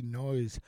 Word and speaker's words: Noyes: Noyes, occasionally. Noyes, yeah. No, but it's no yes. – No yes. – And it's N-O-Y-E-S is no yes, or my husband's nickname is Mr. Maybe.